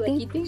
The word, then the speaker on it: Dakiti: like, I think it's called Dakiti, something like that. – Dakiti? Oh, Dakiti? – Yeah. Uh-huh. Okay, I said it right. – Yeah, you did.